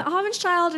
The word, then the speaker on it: Hobbinshield: How do you say my last name? – Hobbinshield? It's close, yeah, it's Hobbinshield. – Hobbinshield, okay. – Hobbinshield, it's like the same thing to me.